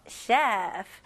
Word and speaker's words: chef: Chef. 네, 이거는 우리나라 말로 셰프라고 많이 하잖아요. 근데 영어로 발음 제대로 하실 거면 셰프가 아니고요. 이름절로 chef. 입술이 붙으면 안 되고요. F 발음 명확히 해주세요.